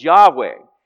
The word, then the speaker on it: Yahweh: Jehovah, and perhaps the more correct pronunciation of that word is Yahweh.